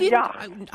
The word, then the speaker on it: yacht: So now you're telling me that I should get a yacht. – You'd probably pronounce it yacht. Who says yacht? – I don't. – G, the H, it's all silent. It's pronounced Y-A-T, yacht.